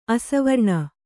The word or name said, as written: ಆಶಾವಾರಣ